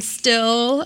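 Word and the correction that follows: Stihl: It's pronounced steel. – Steel. Okay.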